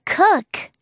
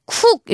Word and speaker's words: cook: 친구들 요리사 발음할 때 쿡 이렇게 우 발음 살려서 막 쿡 이렇게 하지 마시고요. 약간 우리말의 으 발음처럼 으으 우가 아니라 으 사운드처럼 He's a cook. He's a cook. 그렇게 발음해주면 좋아요.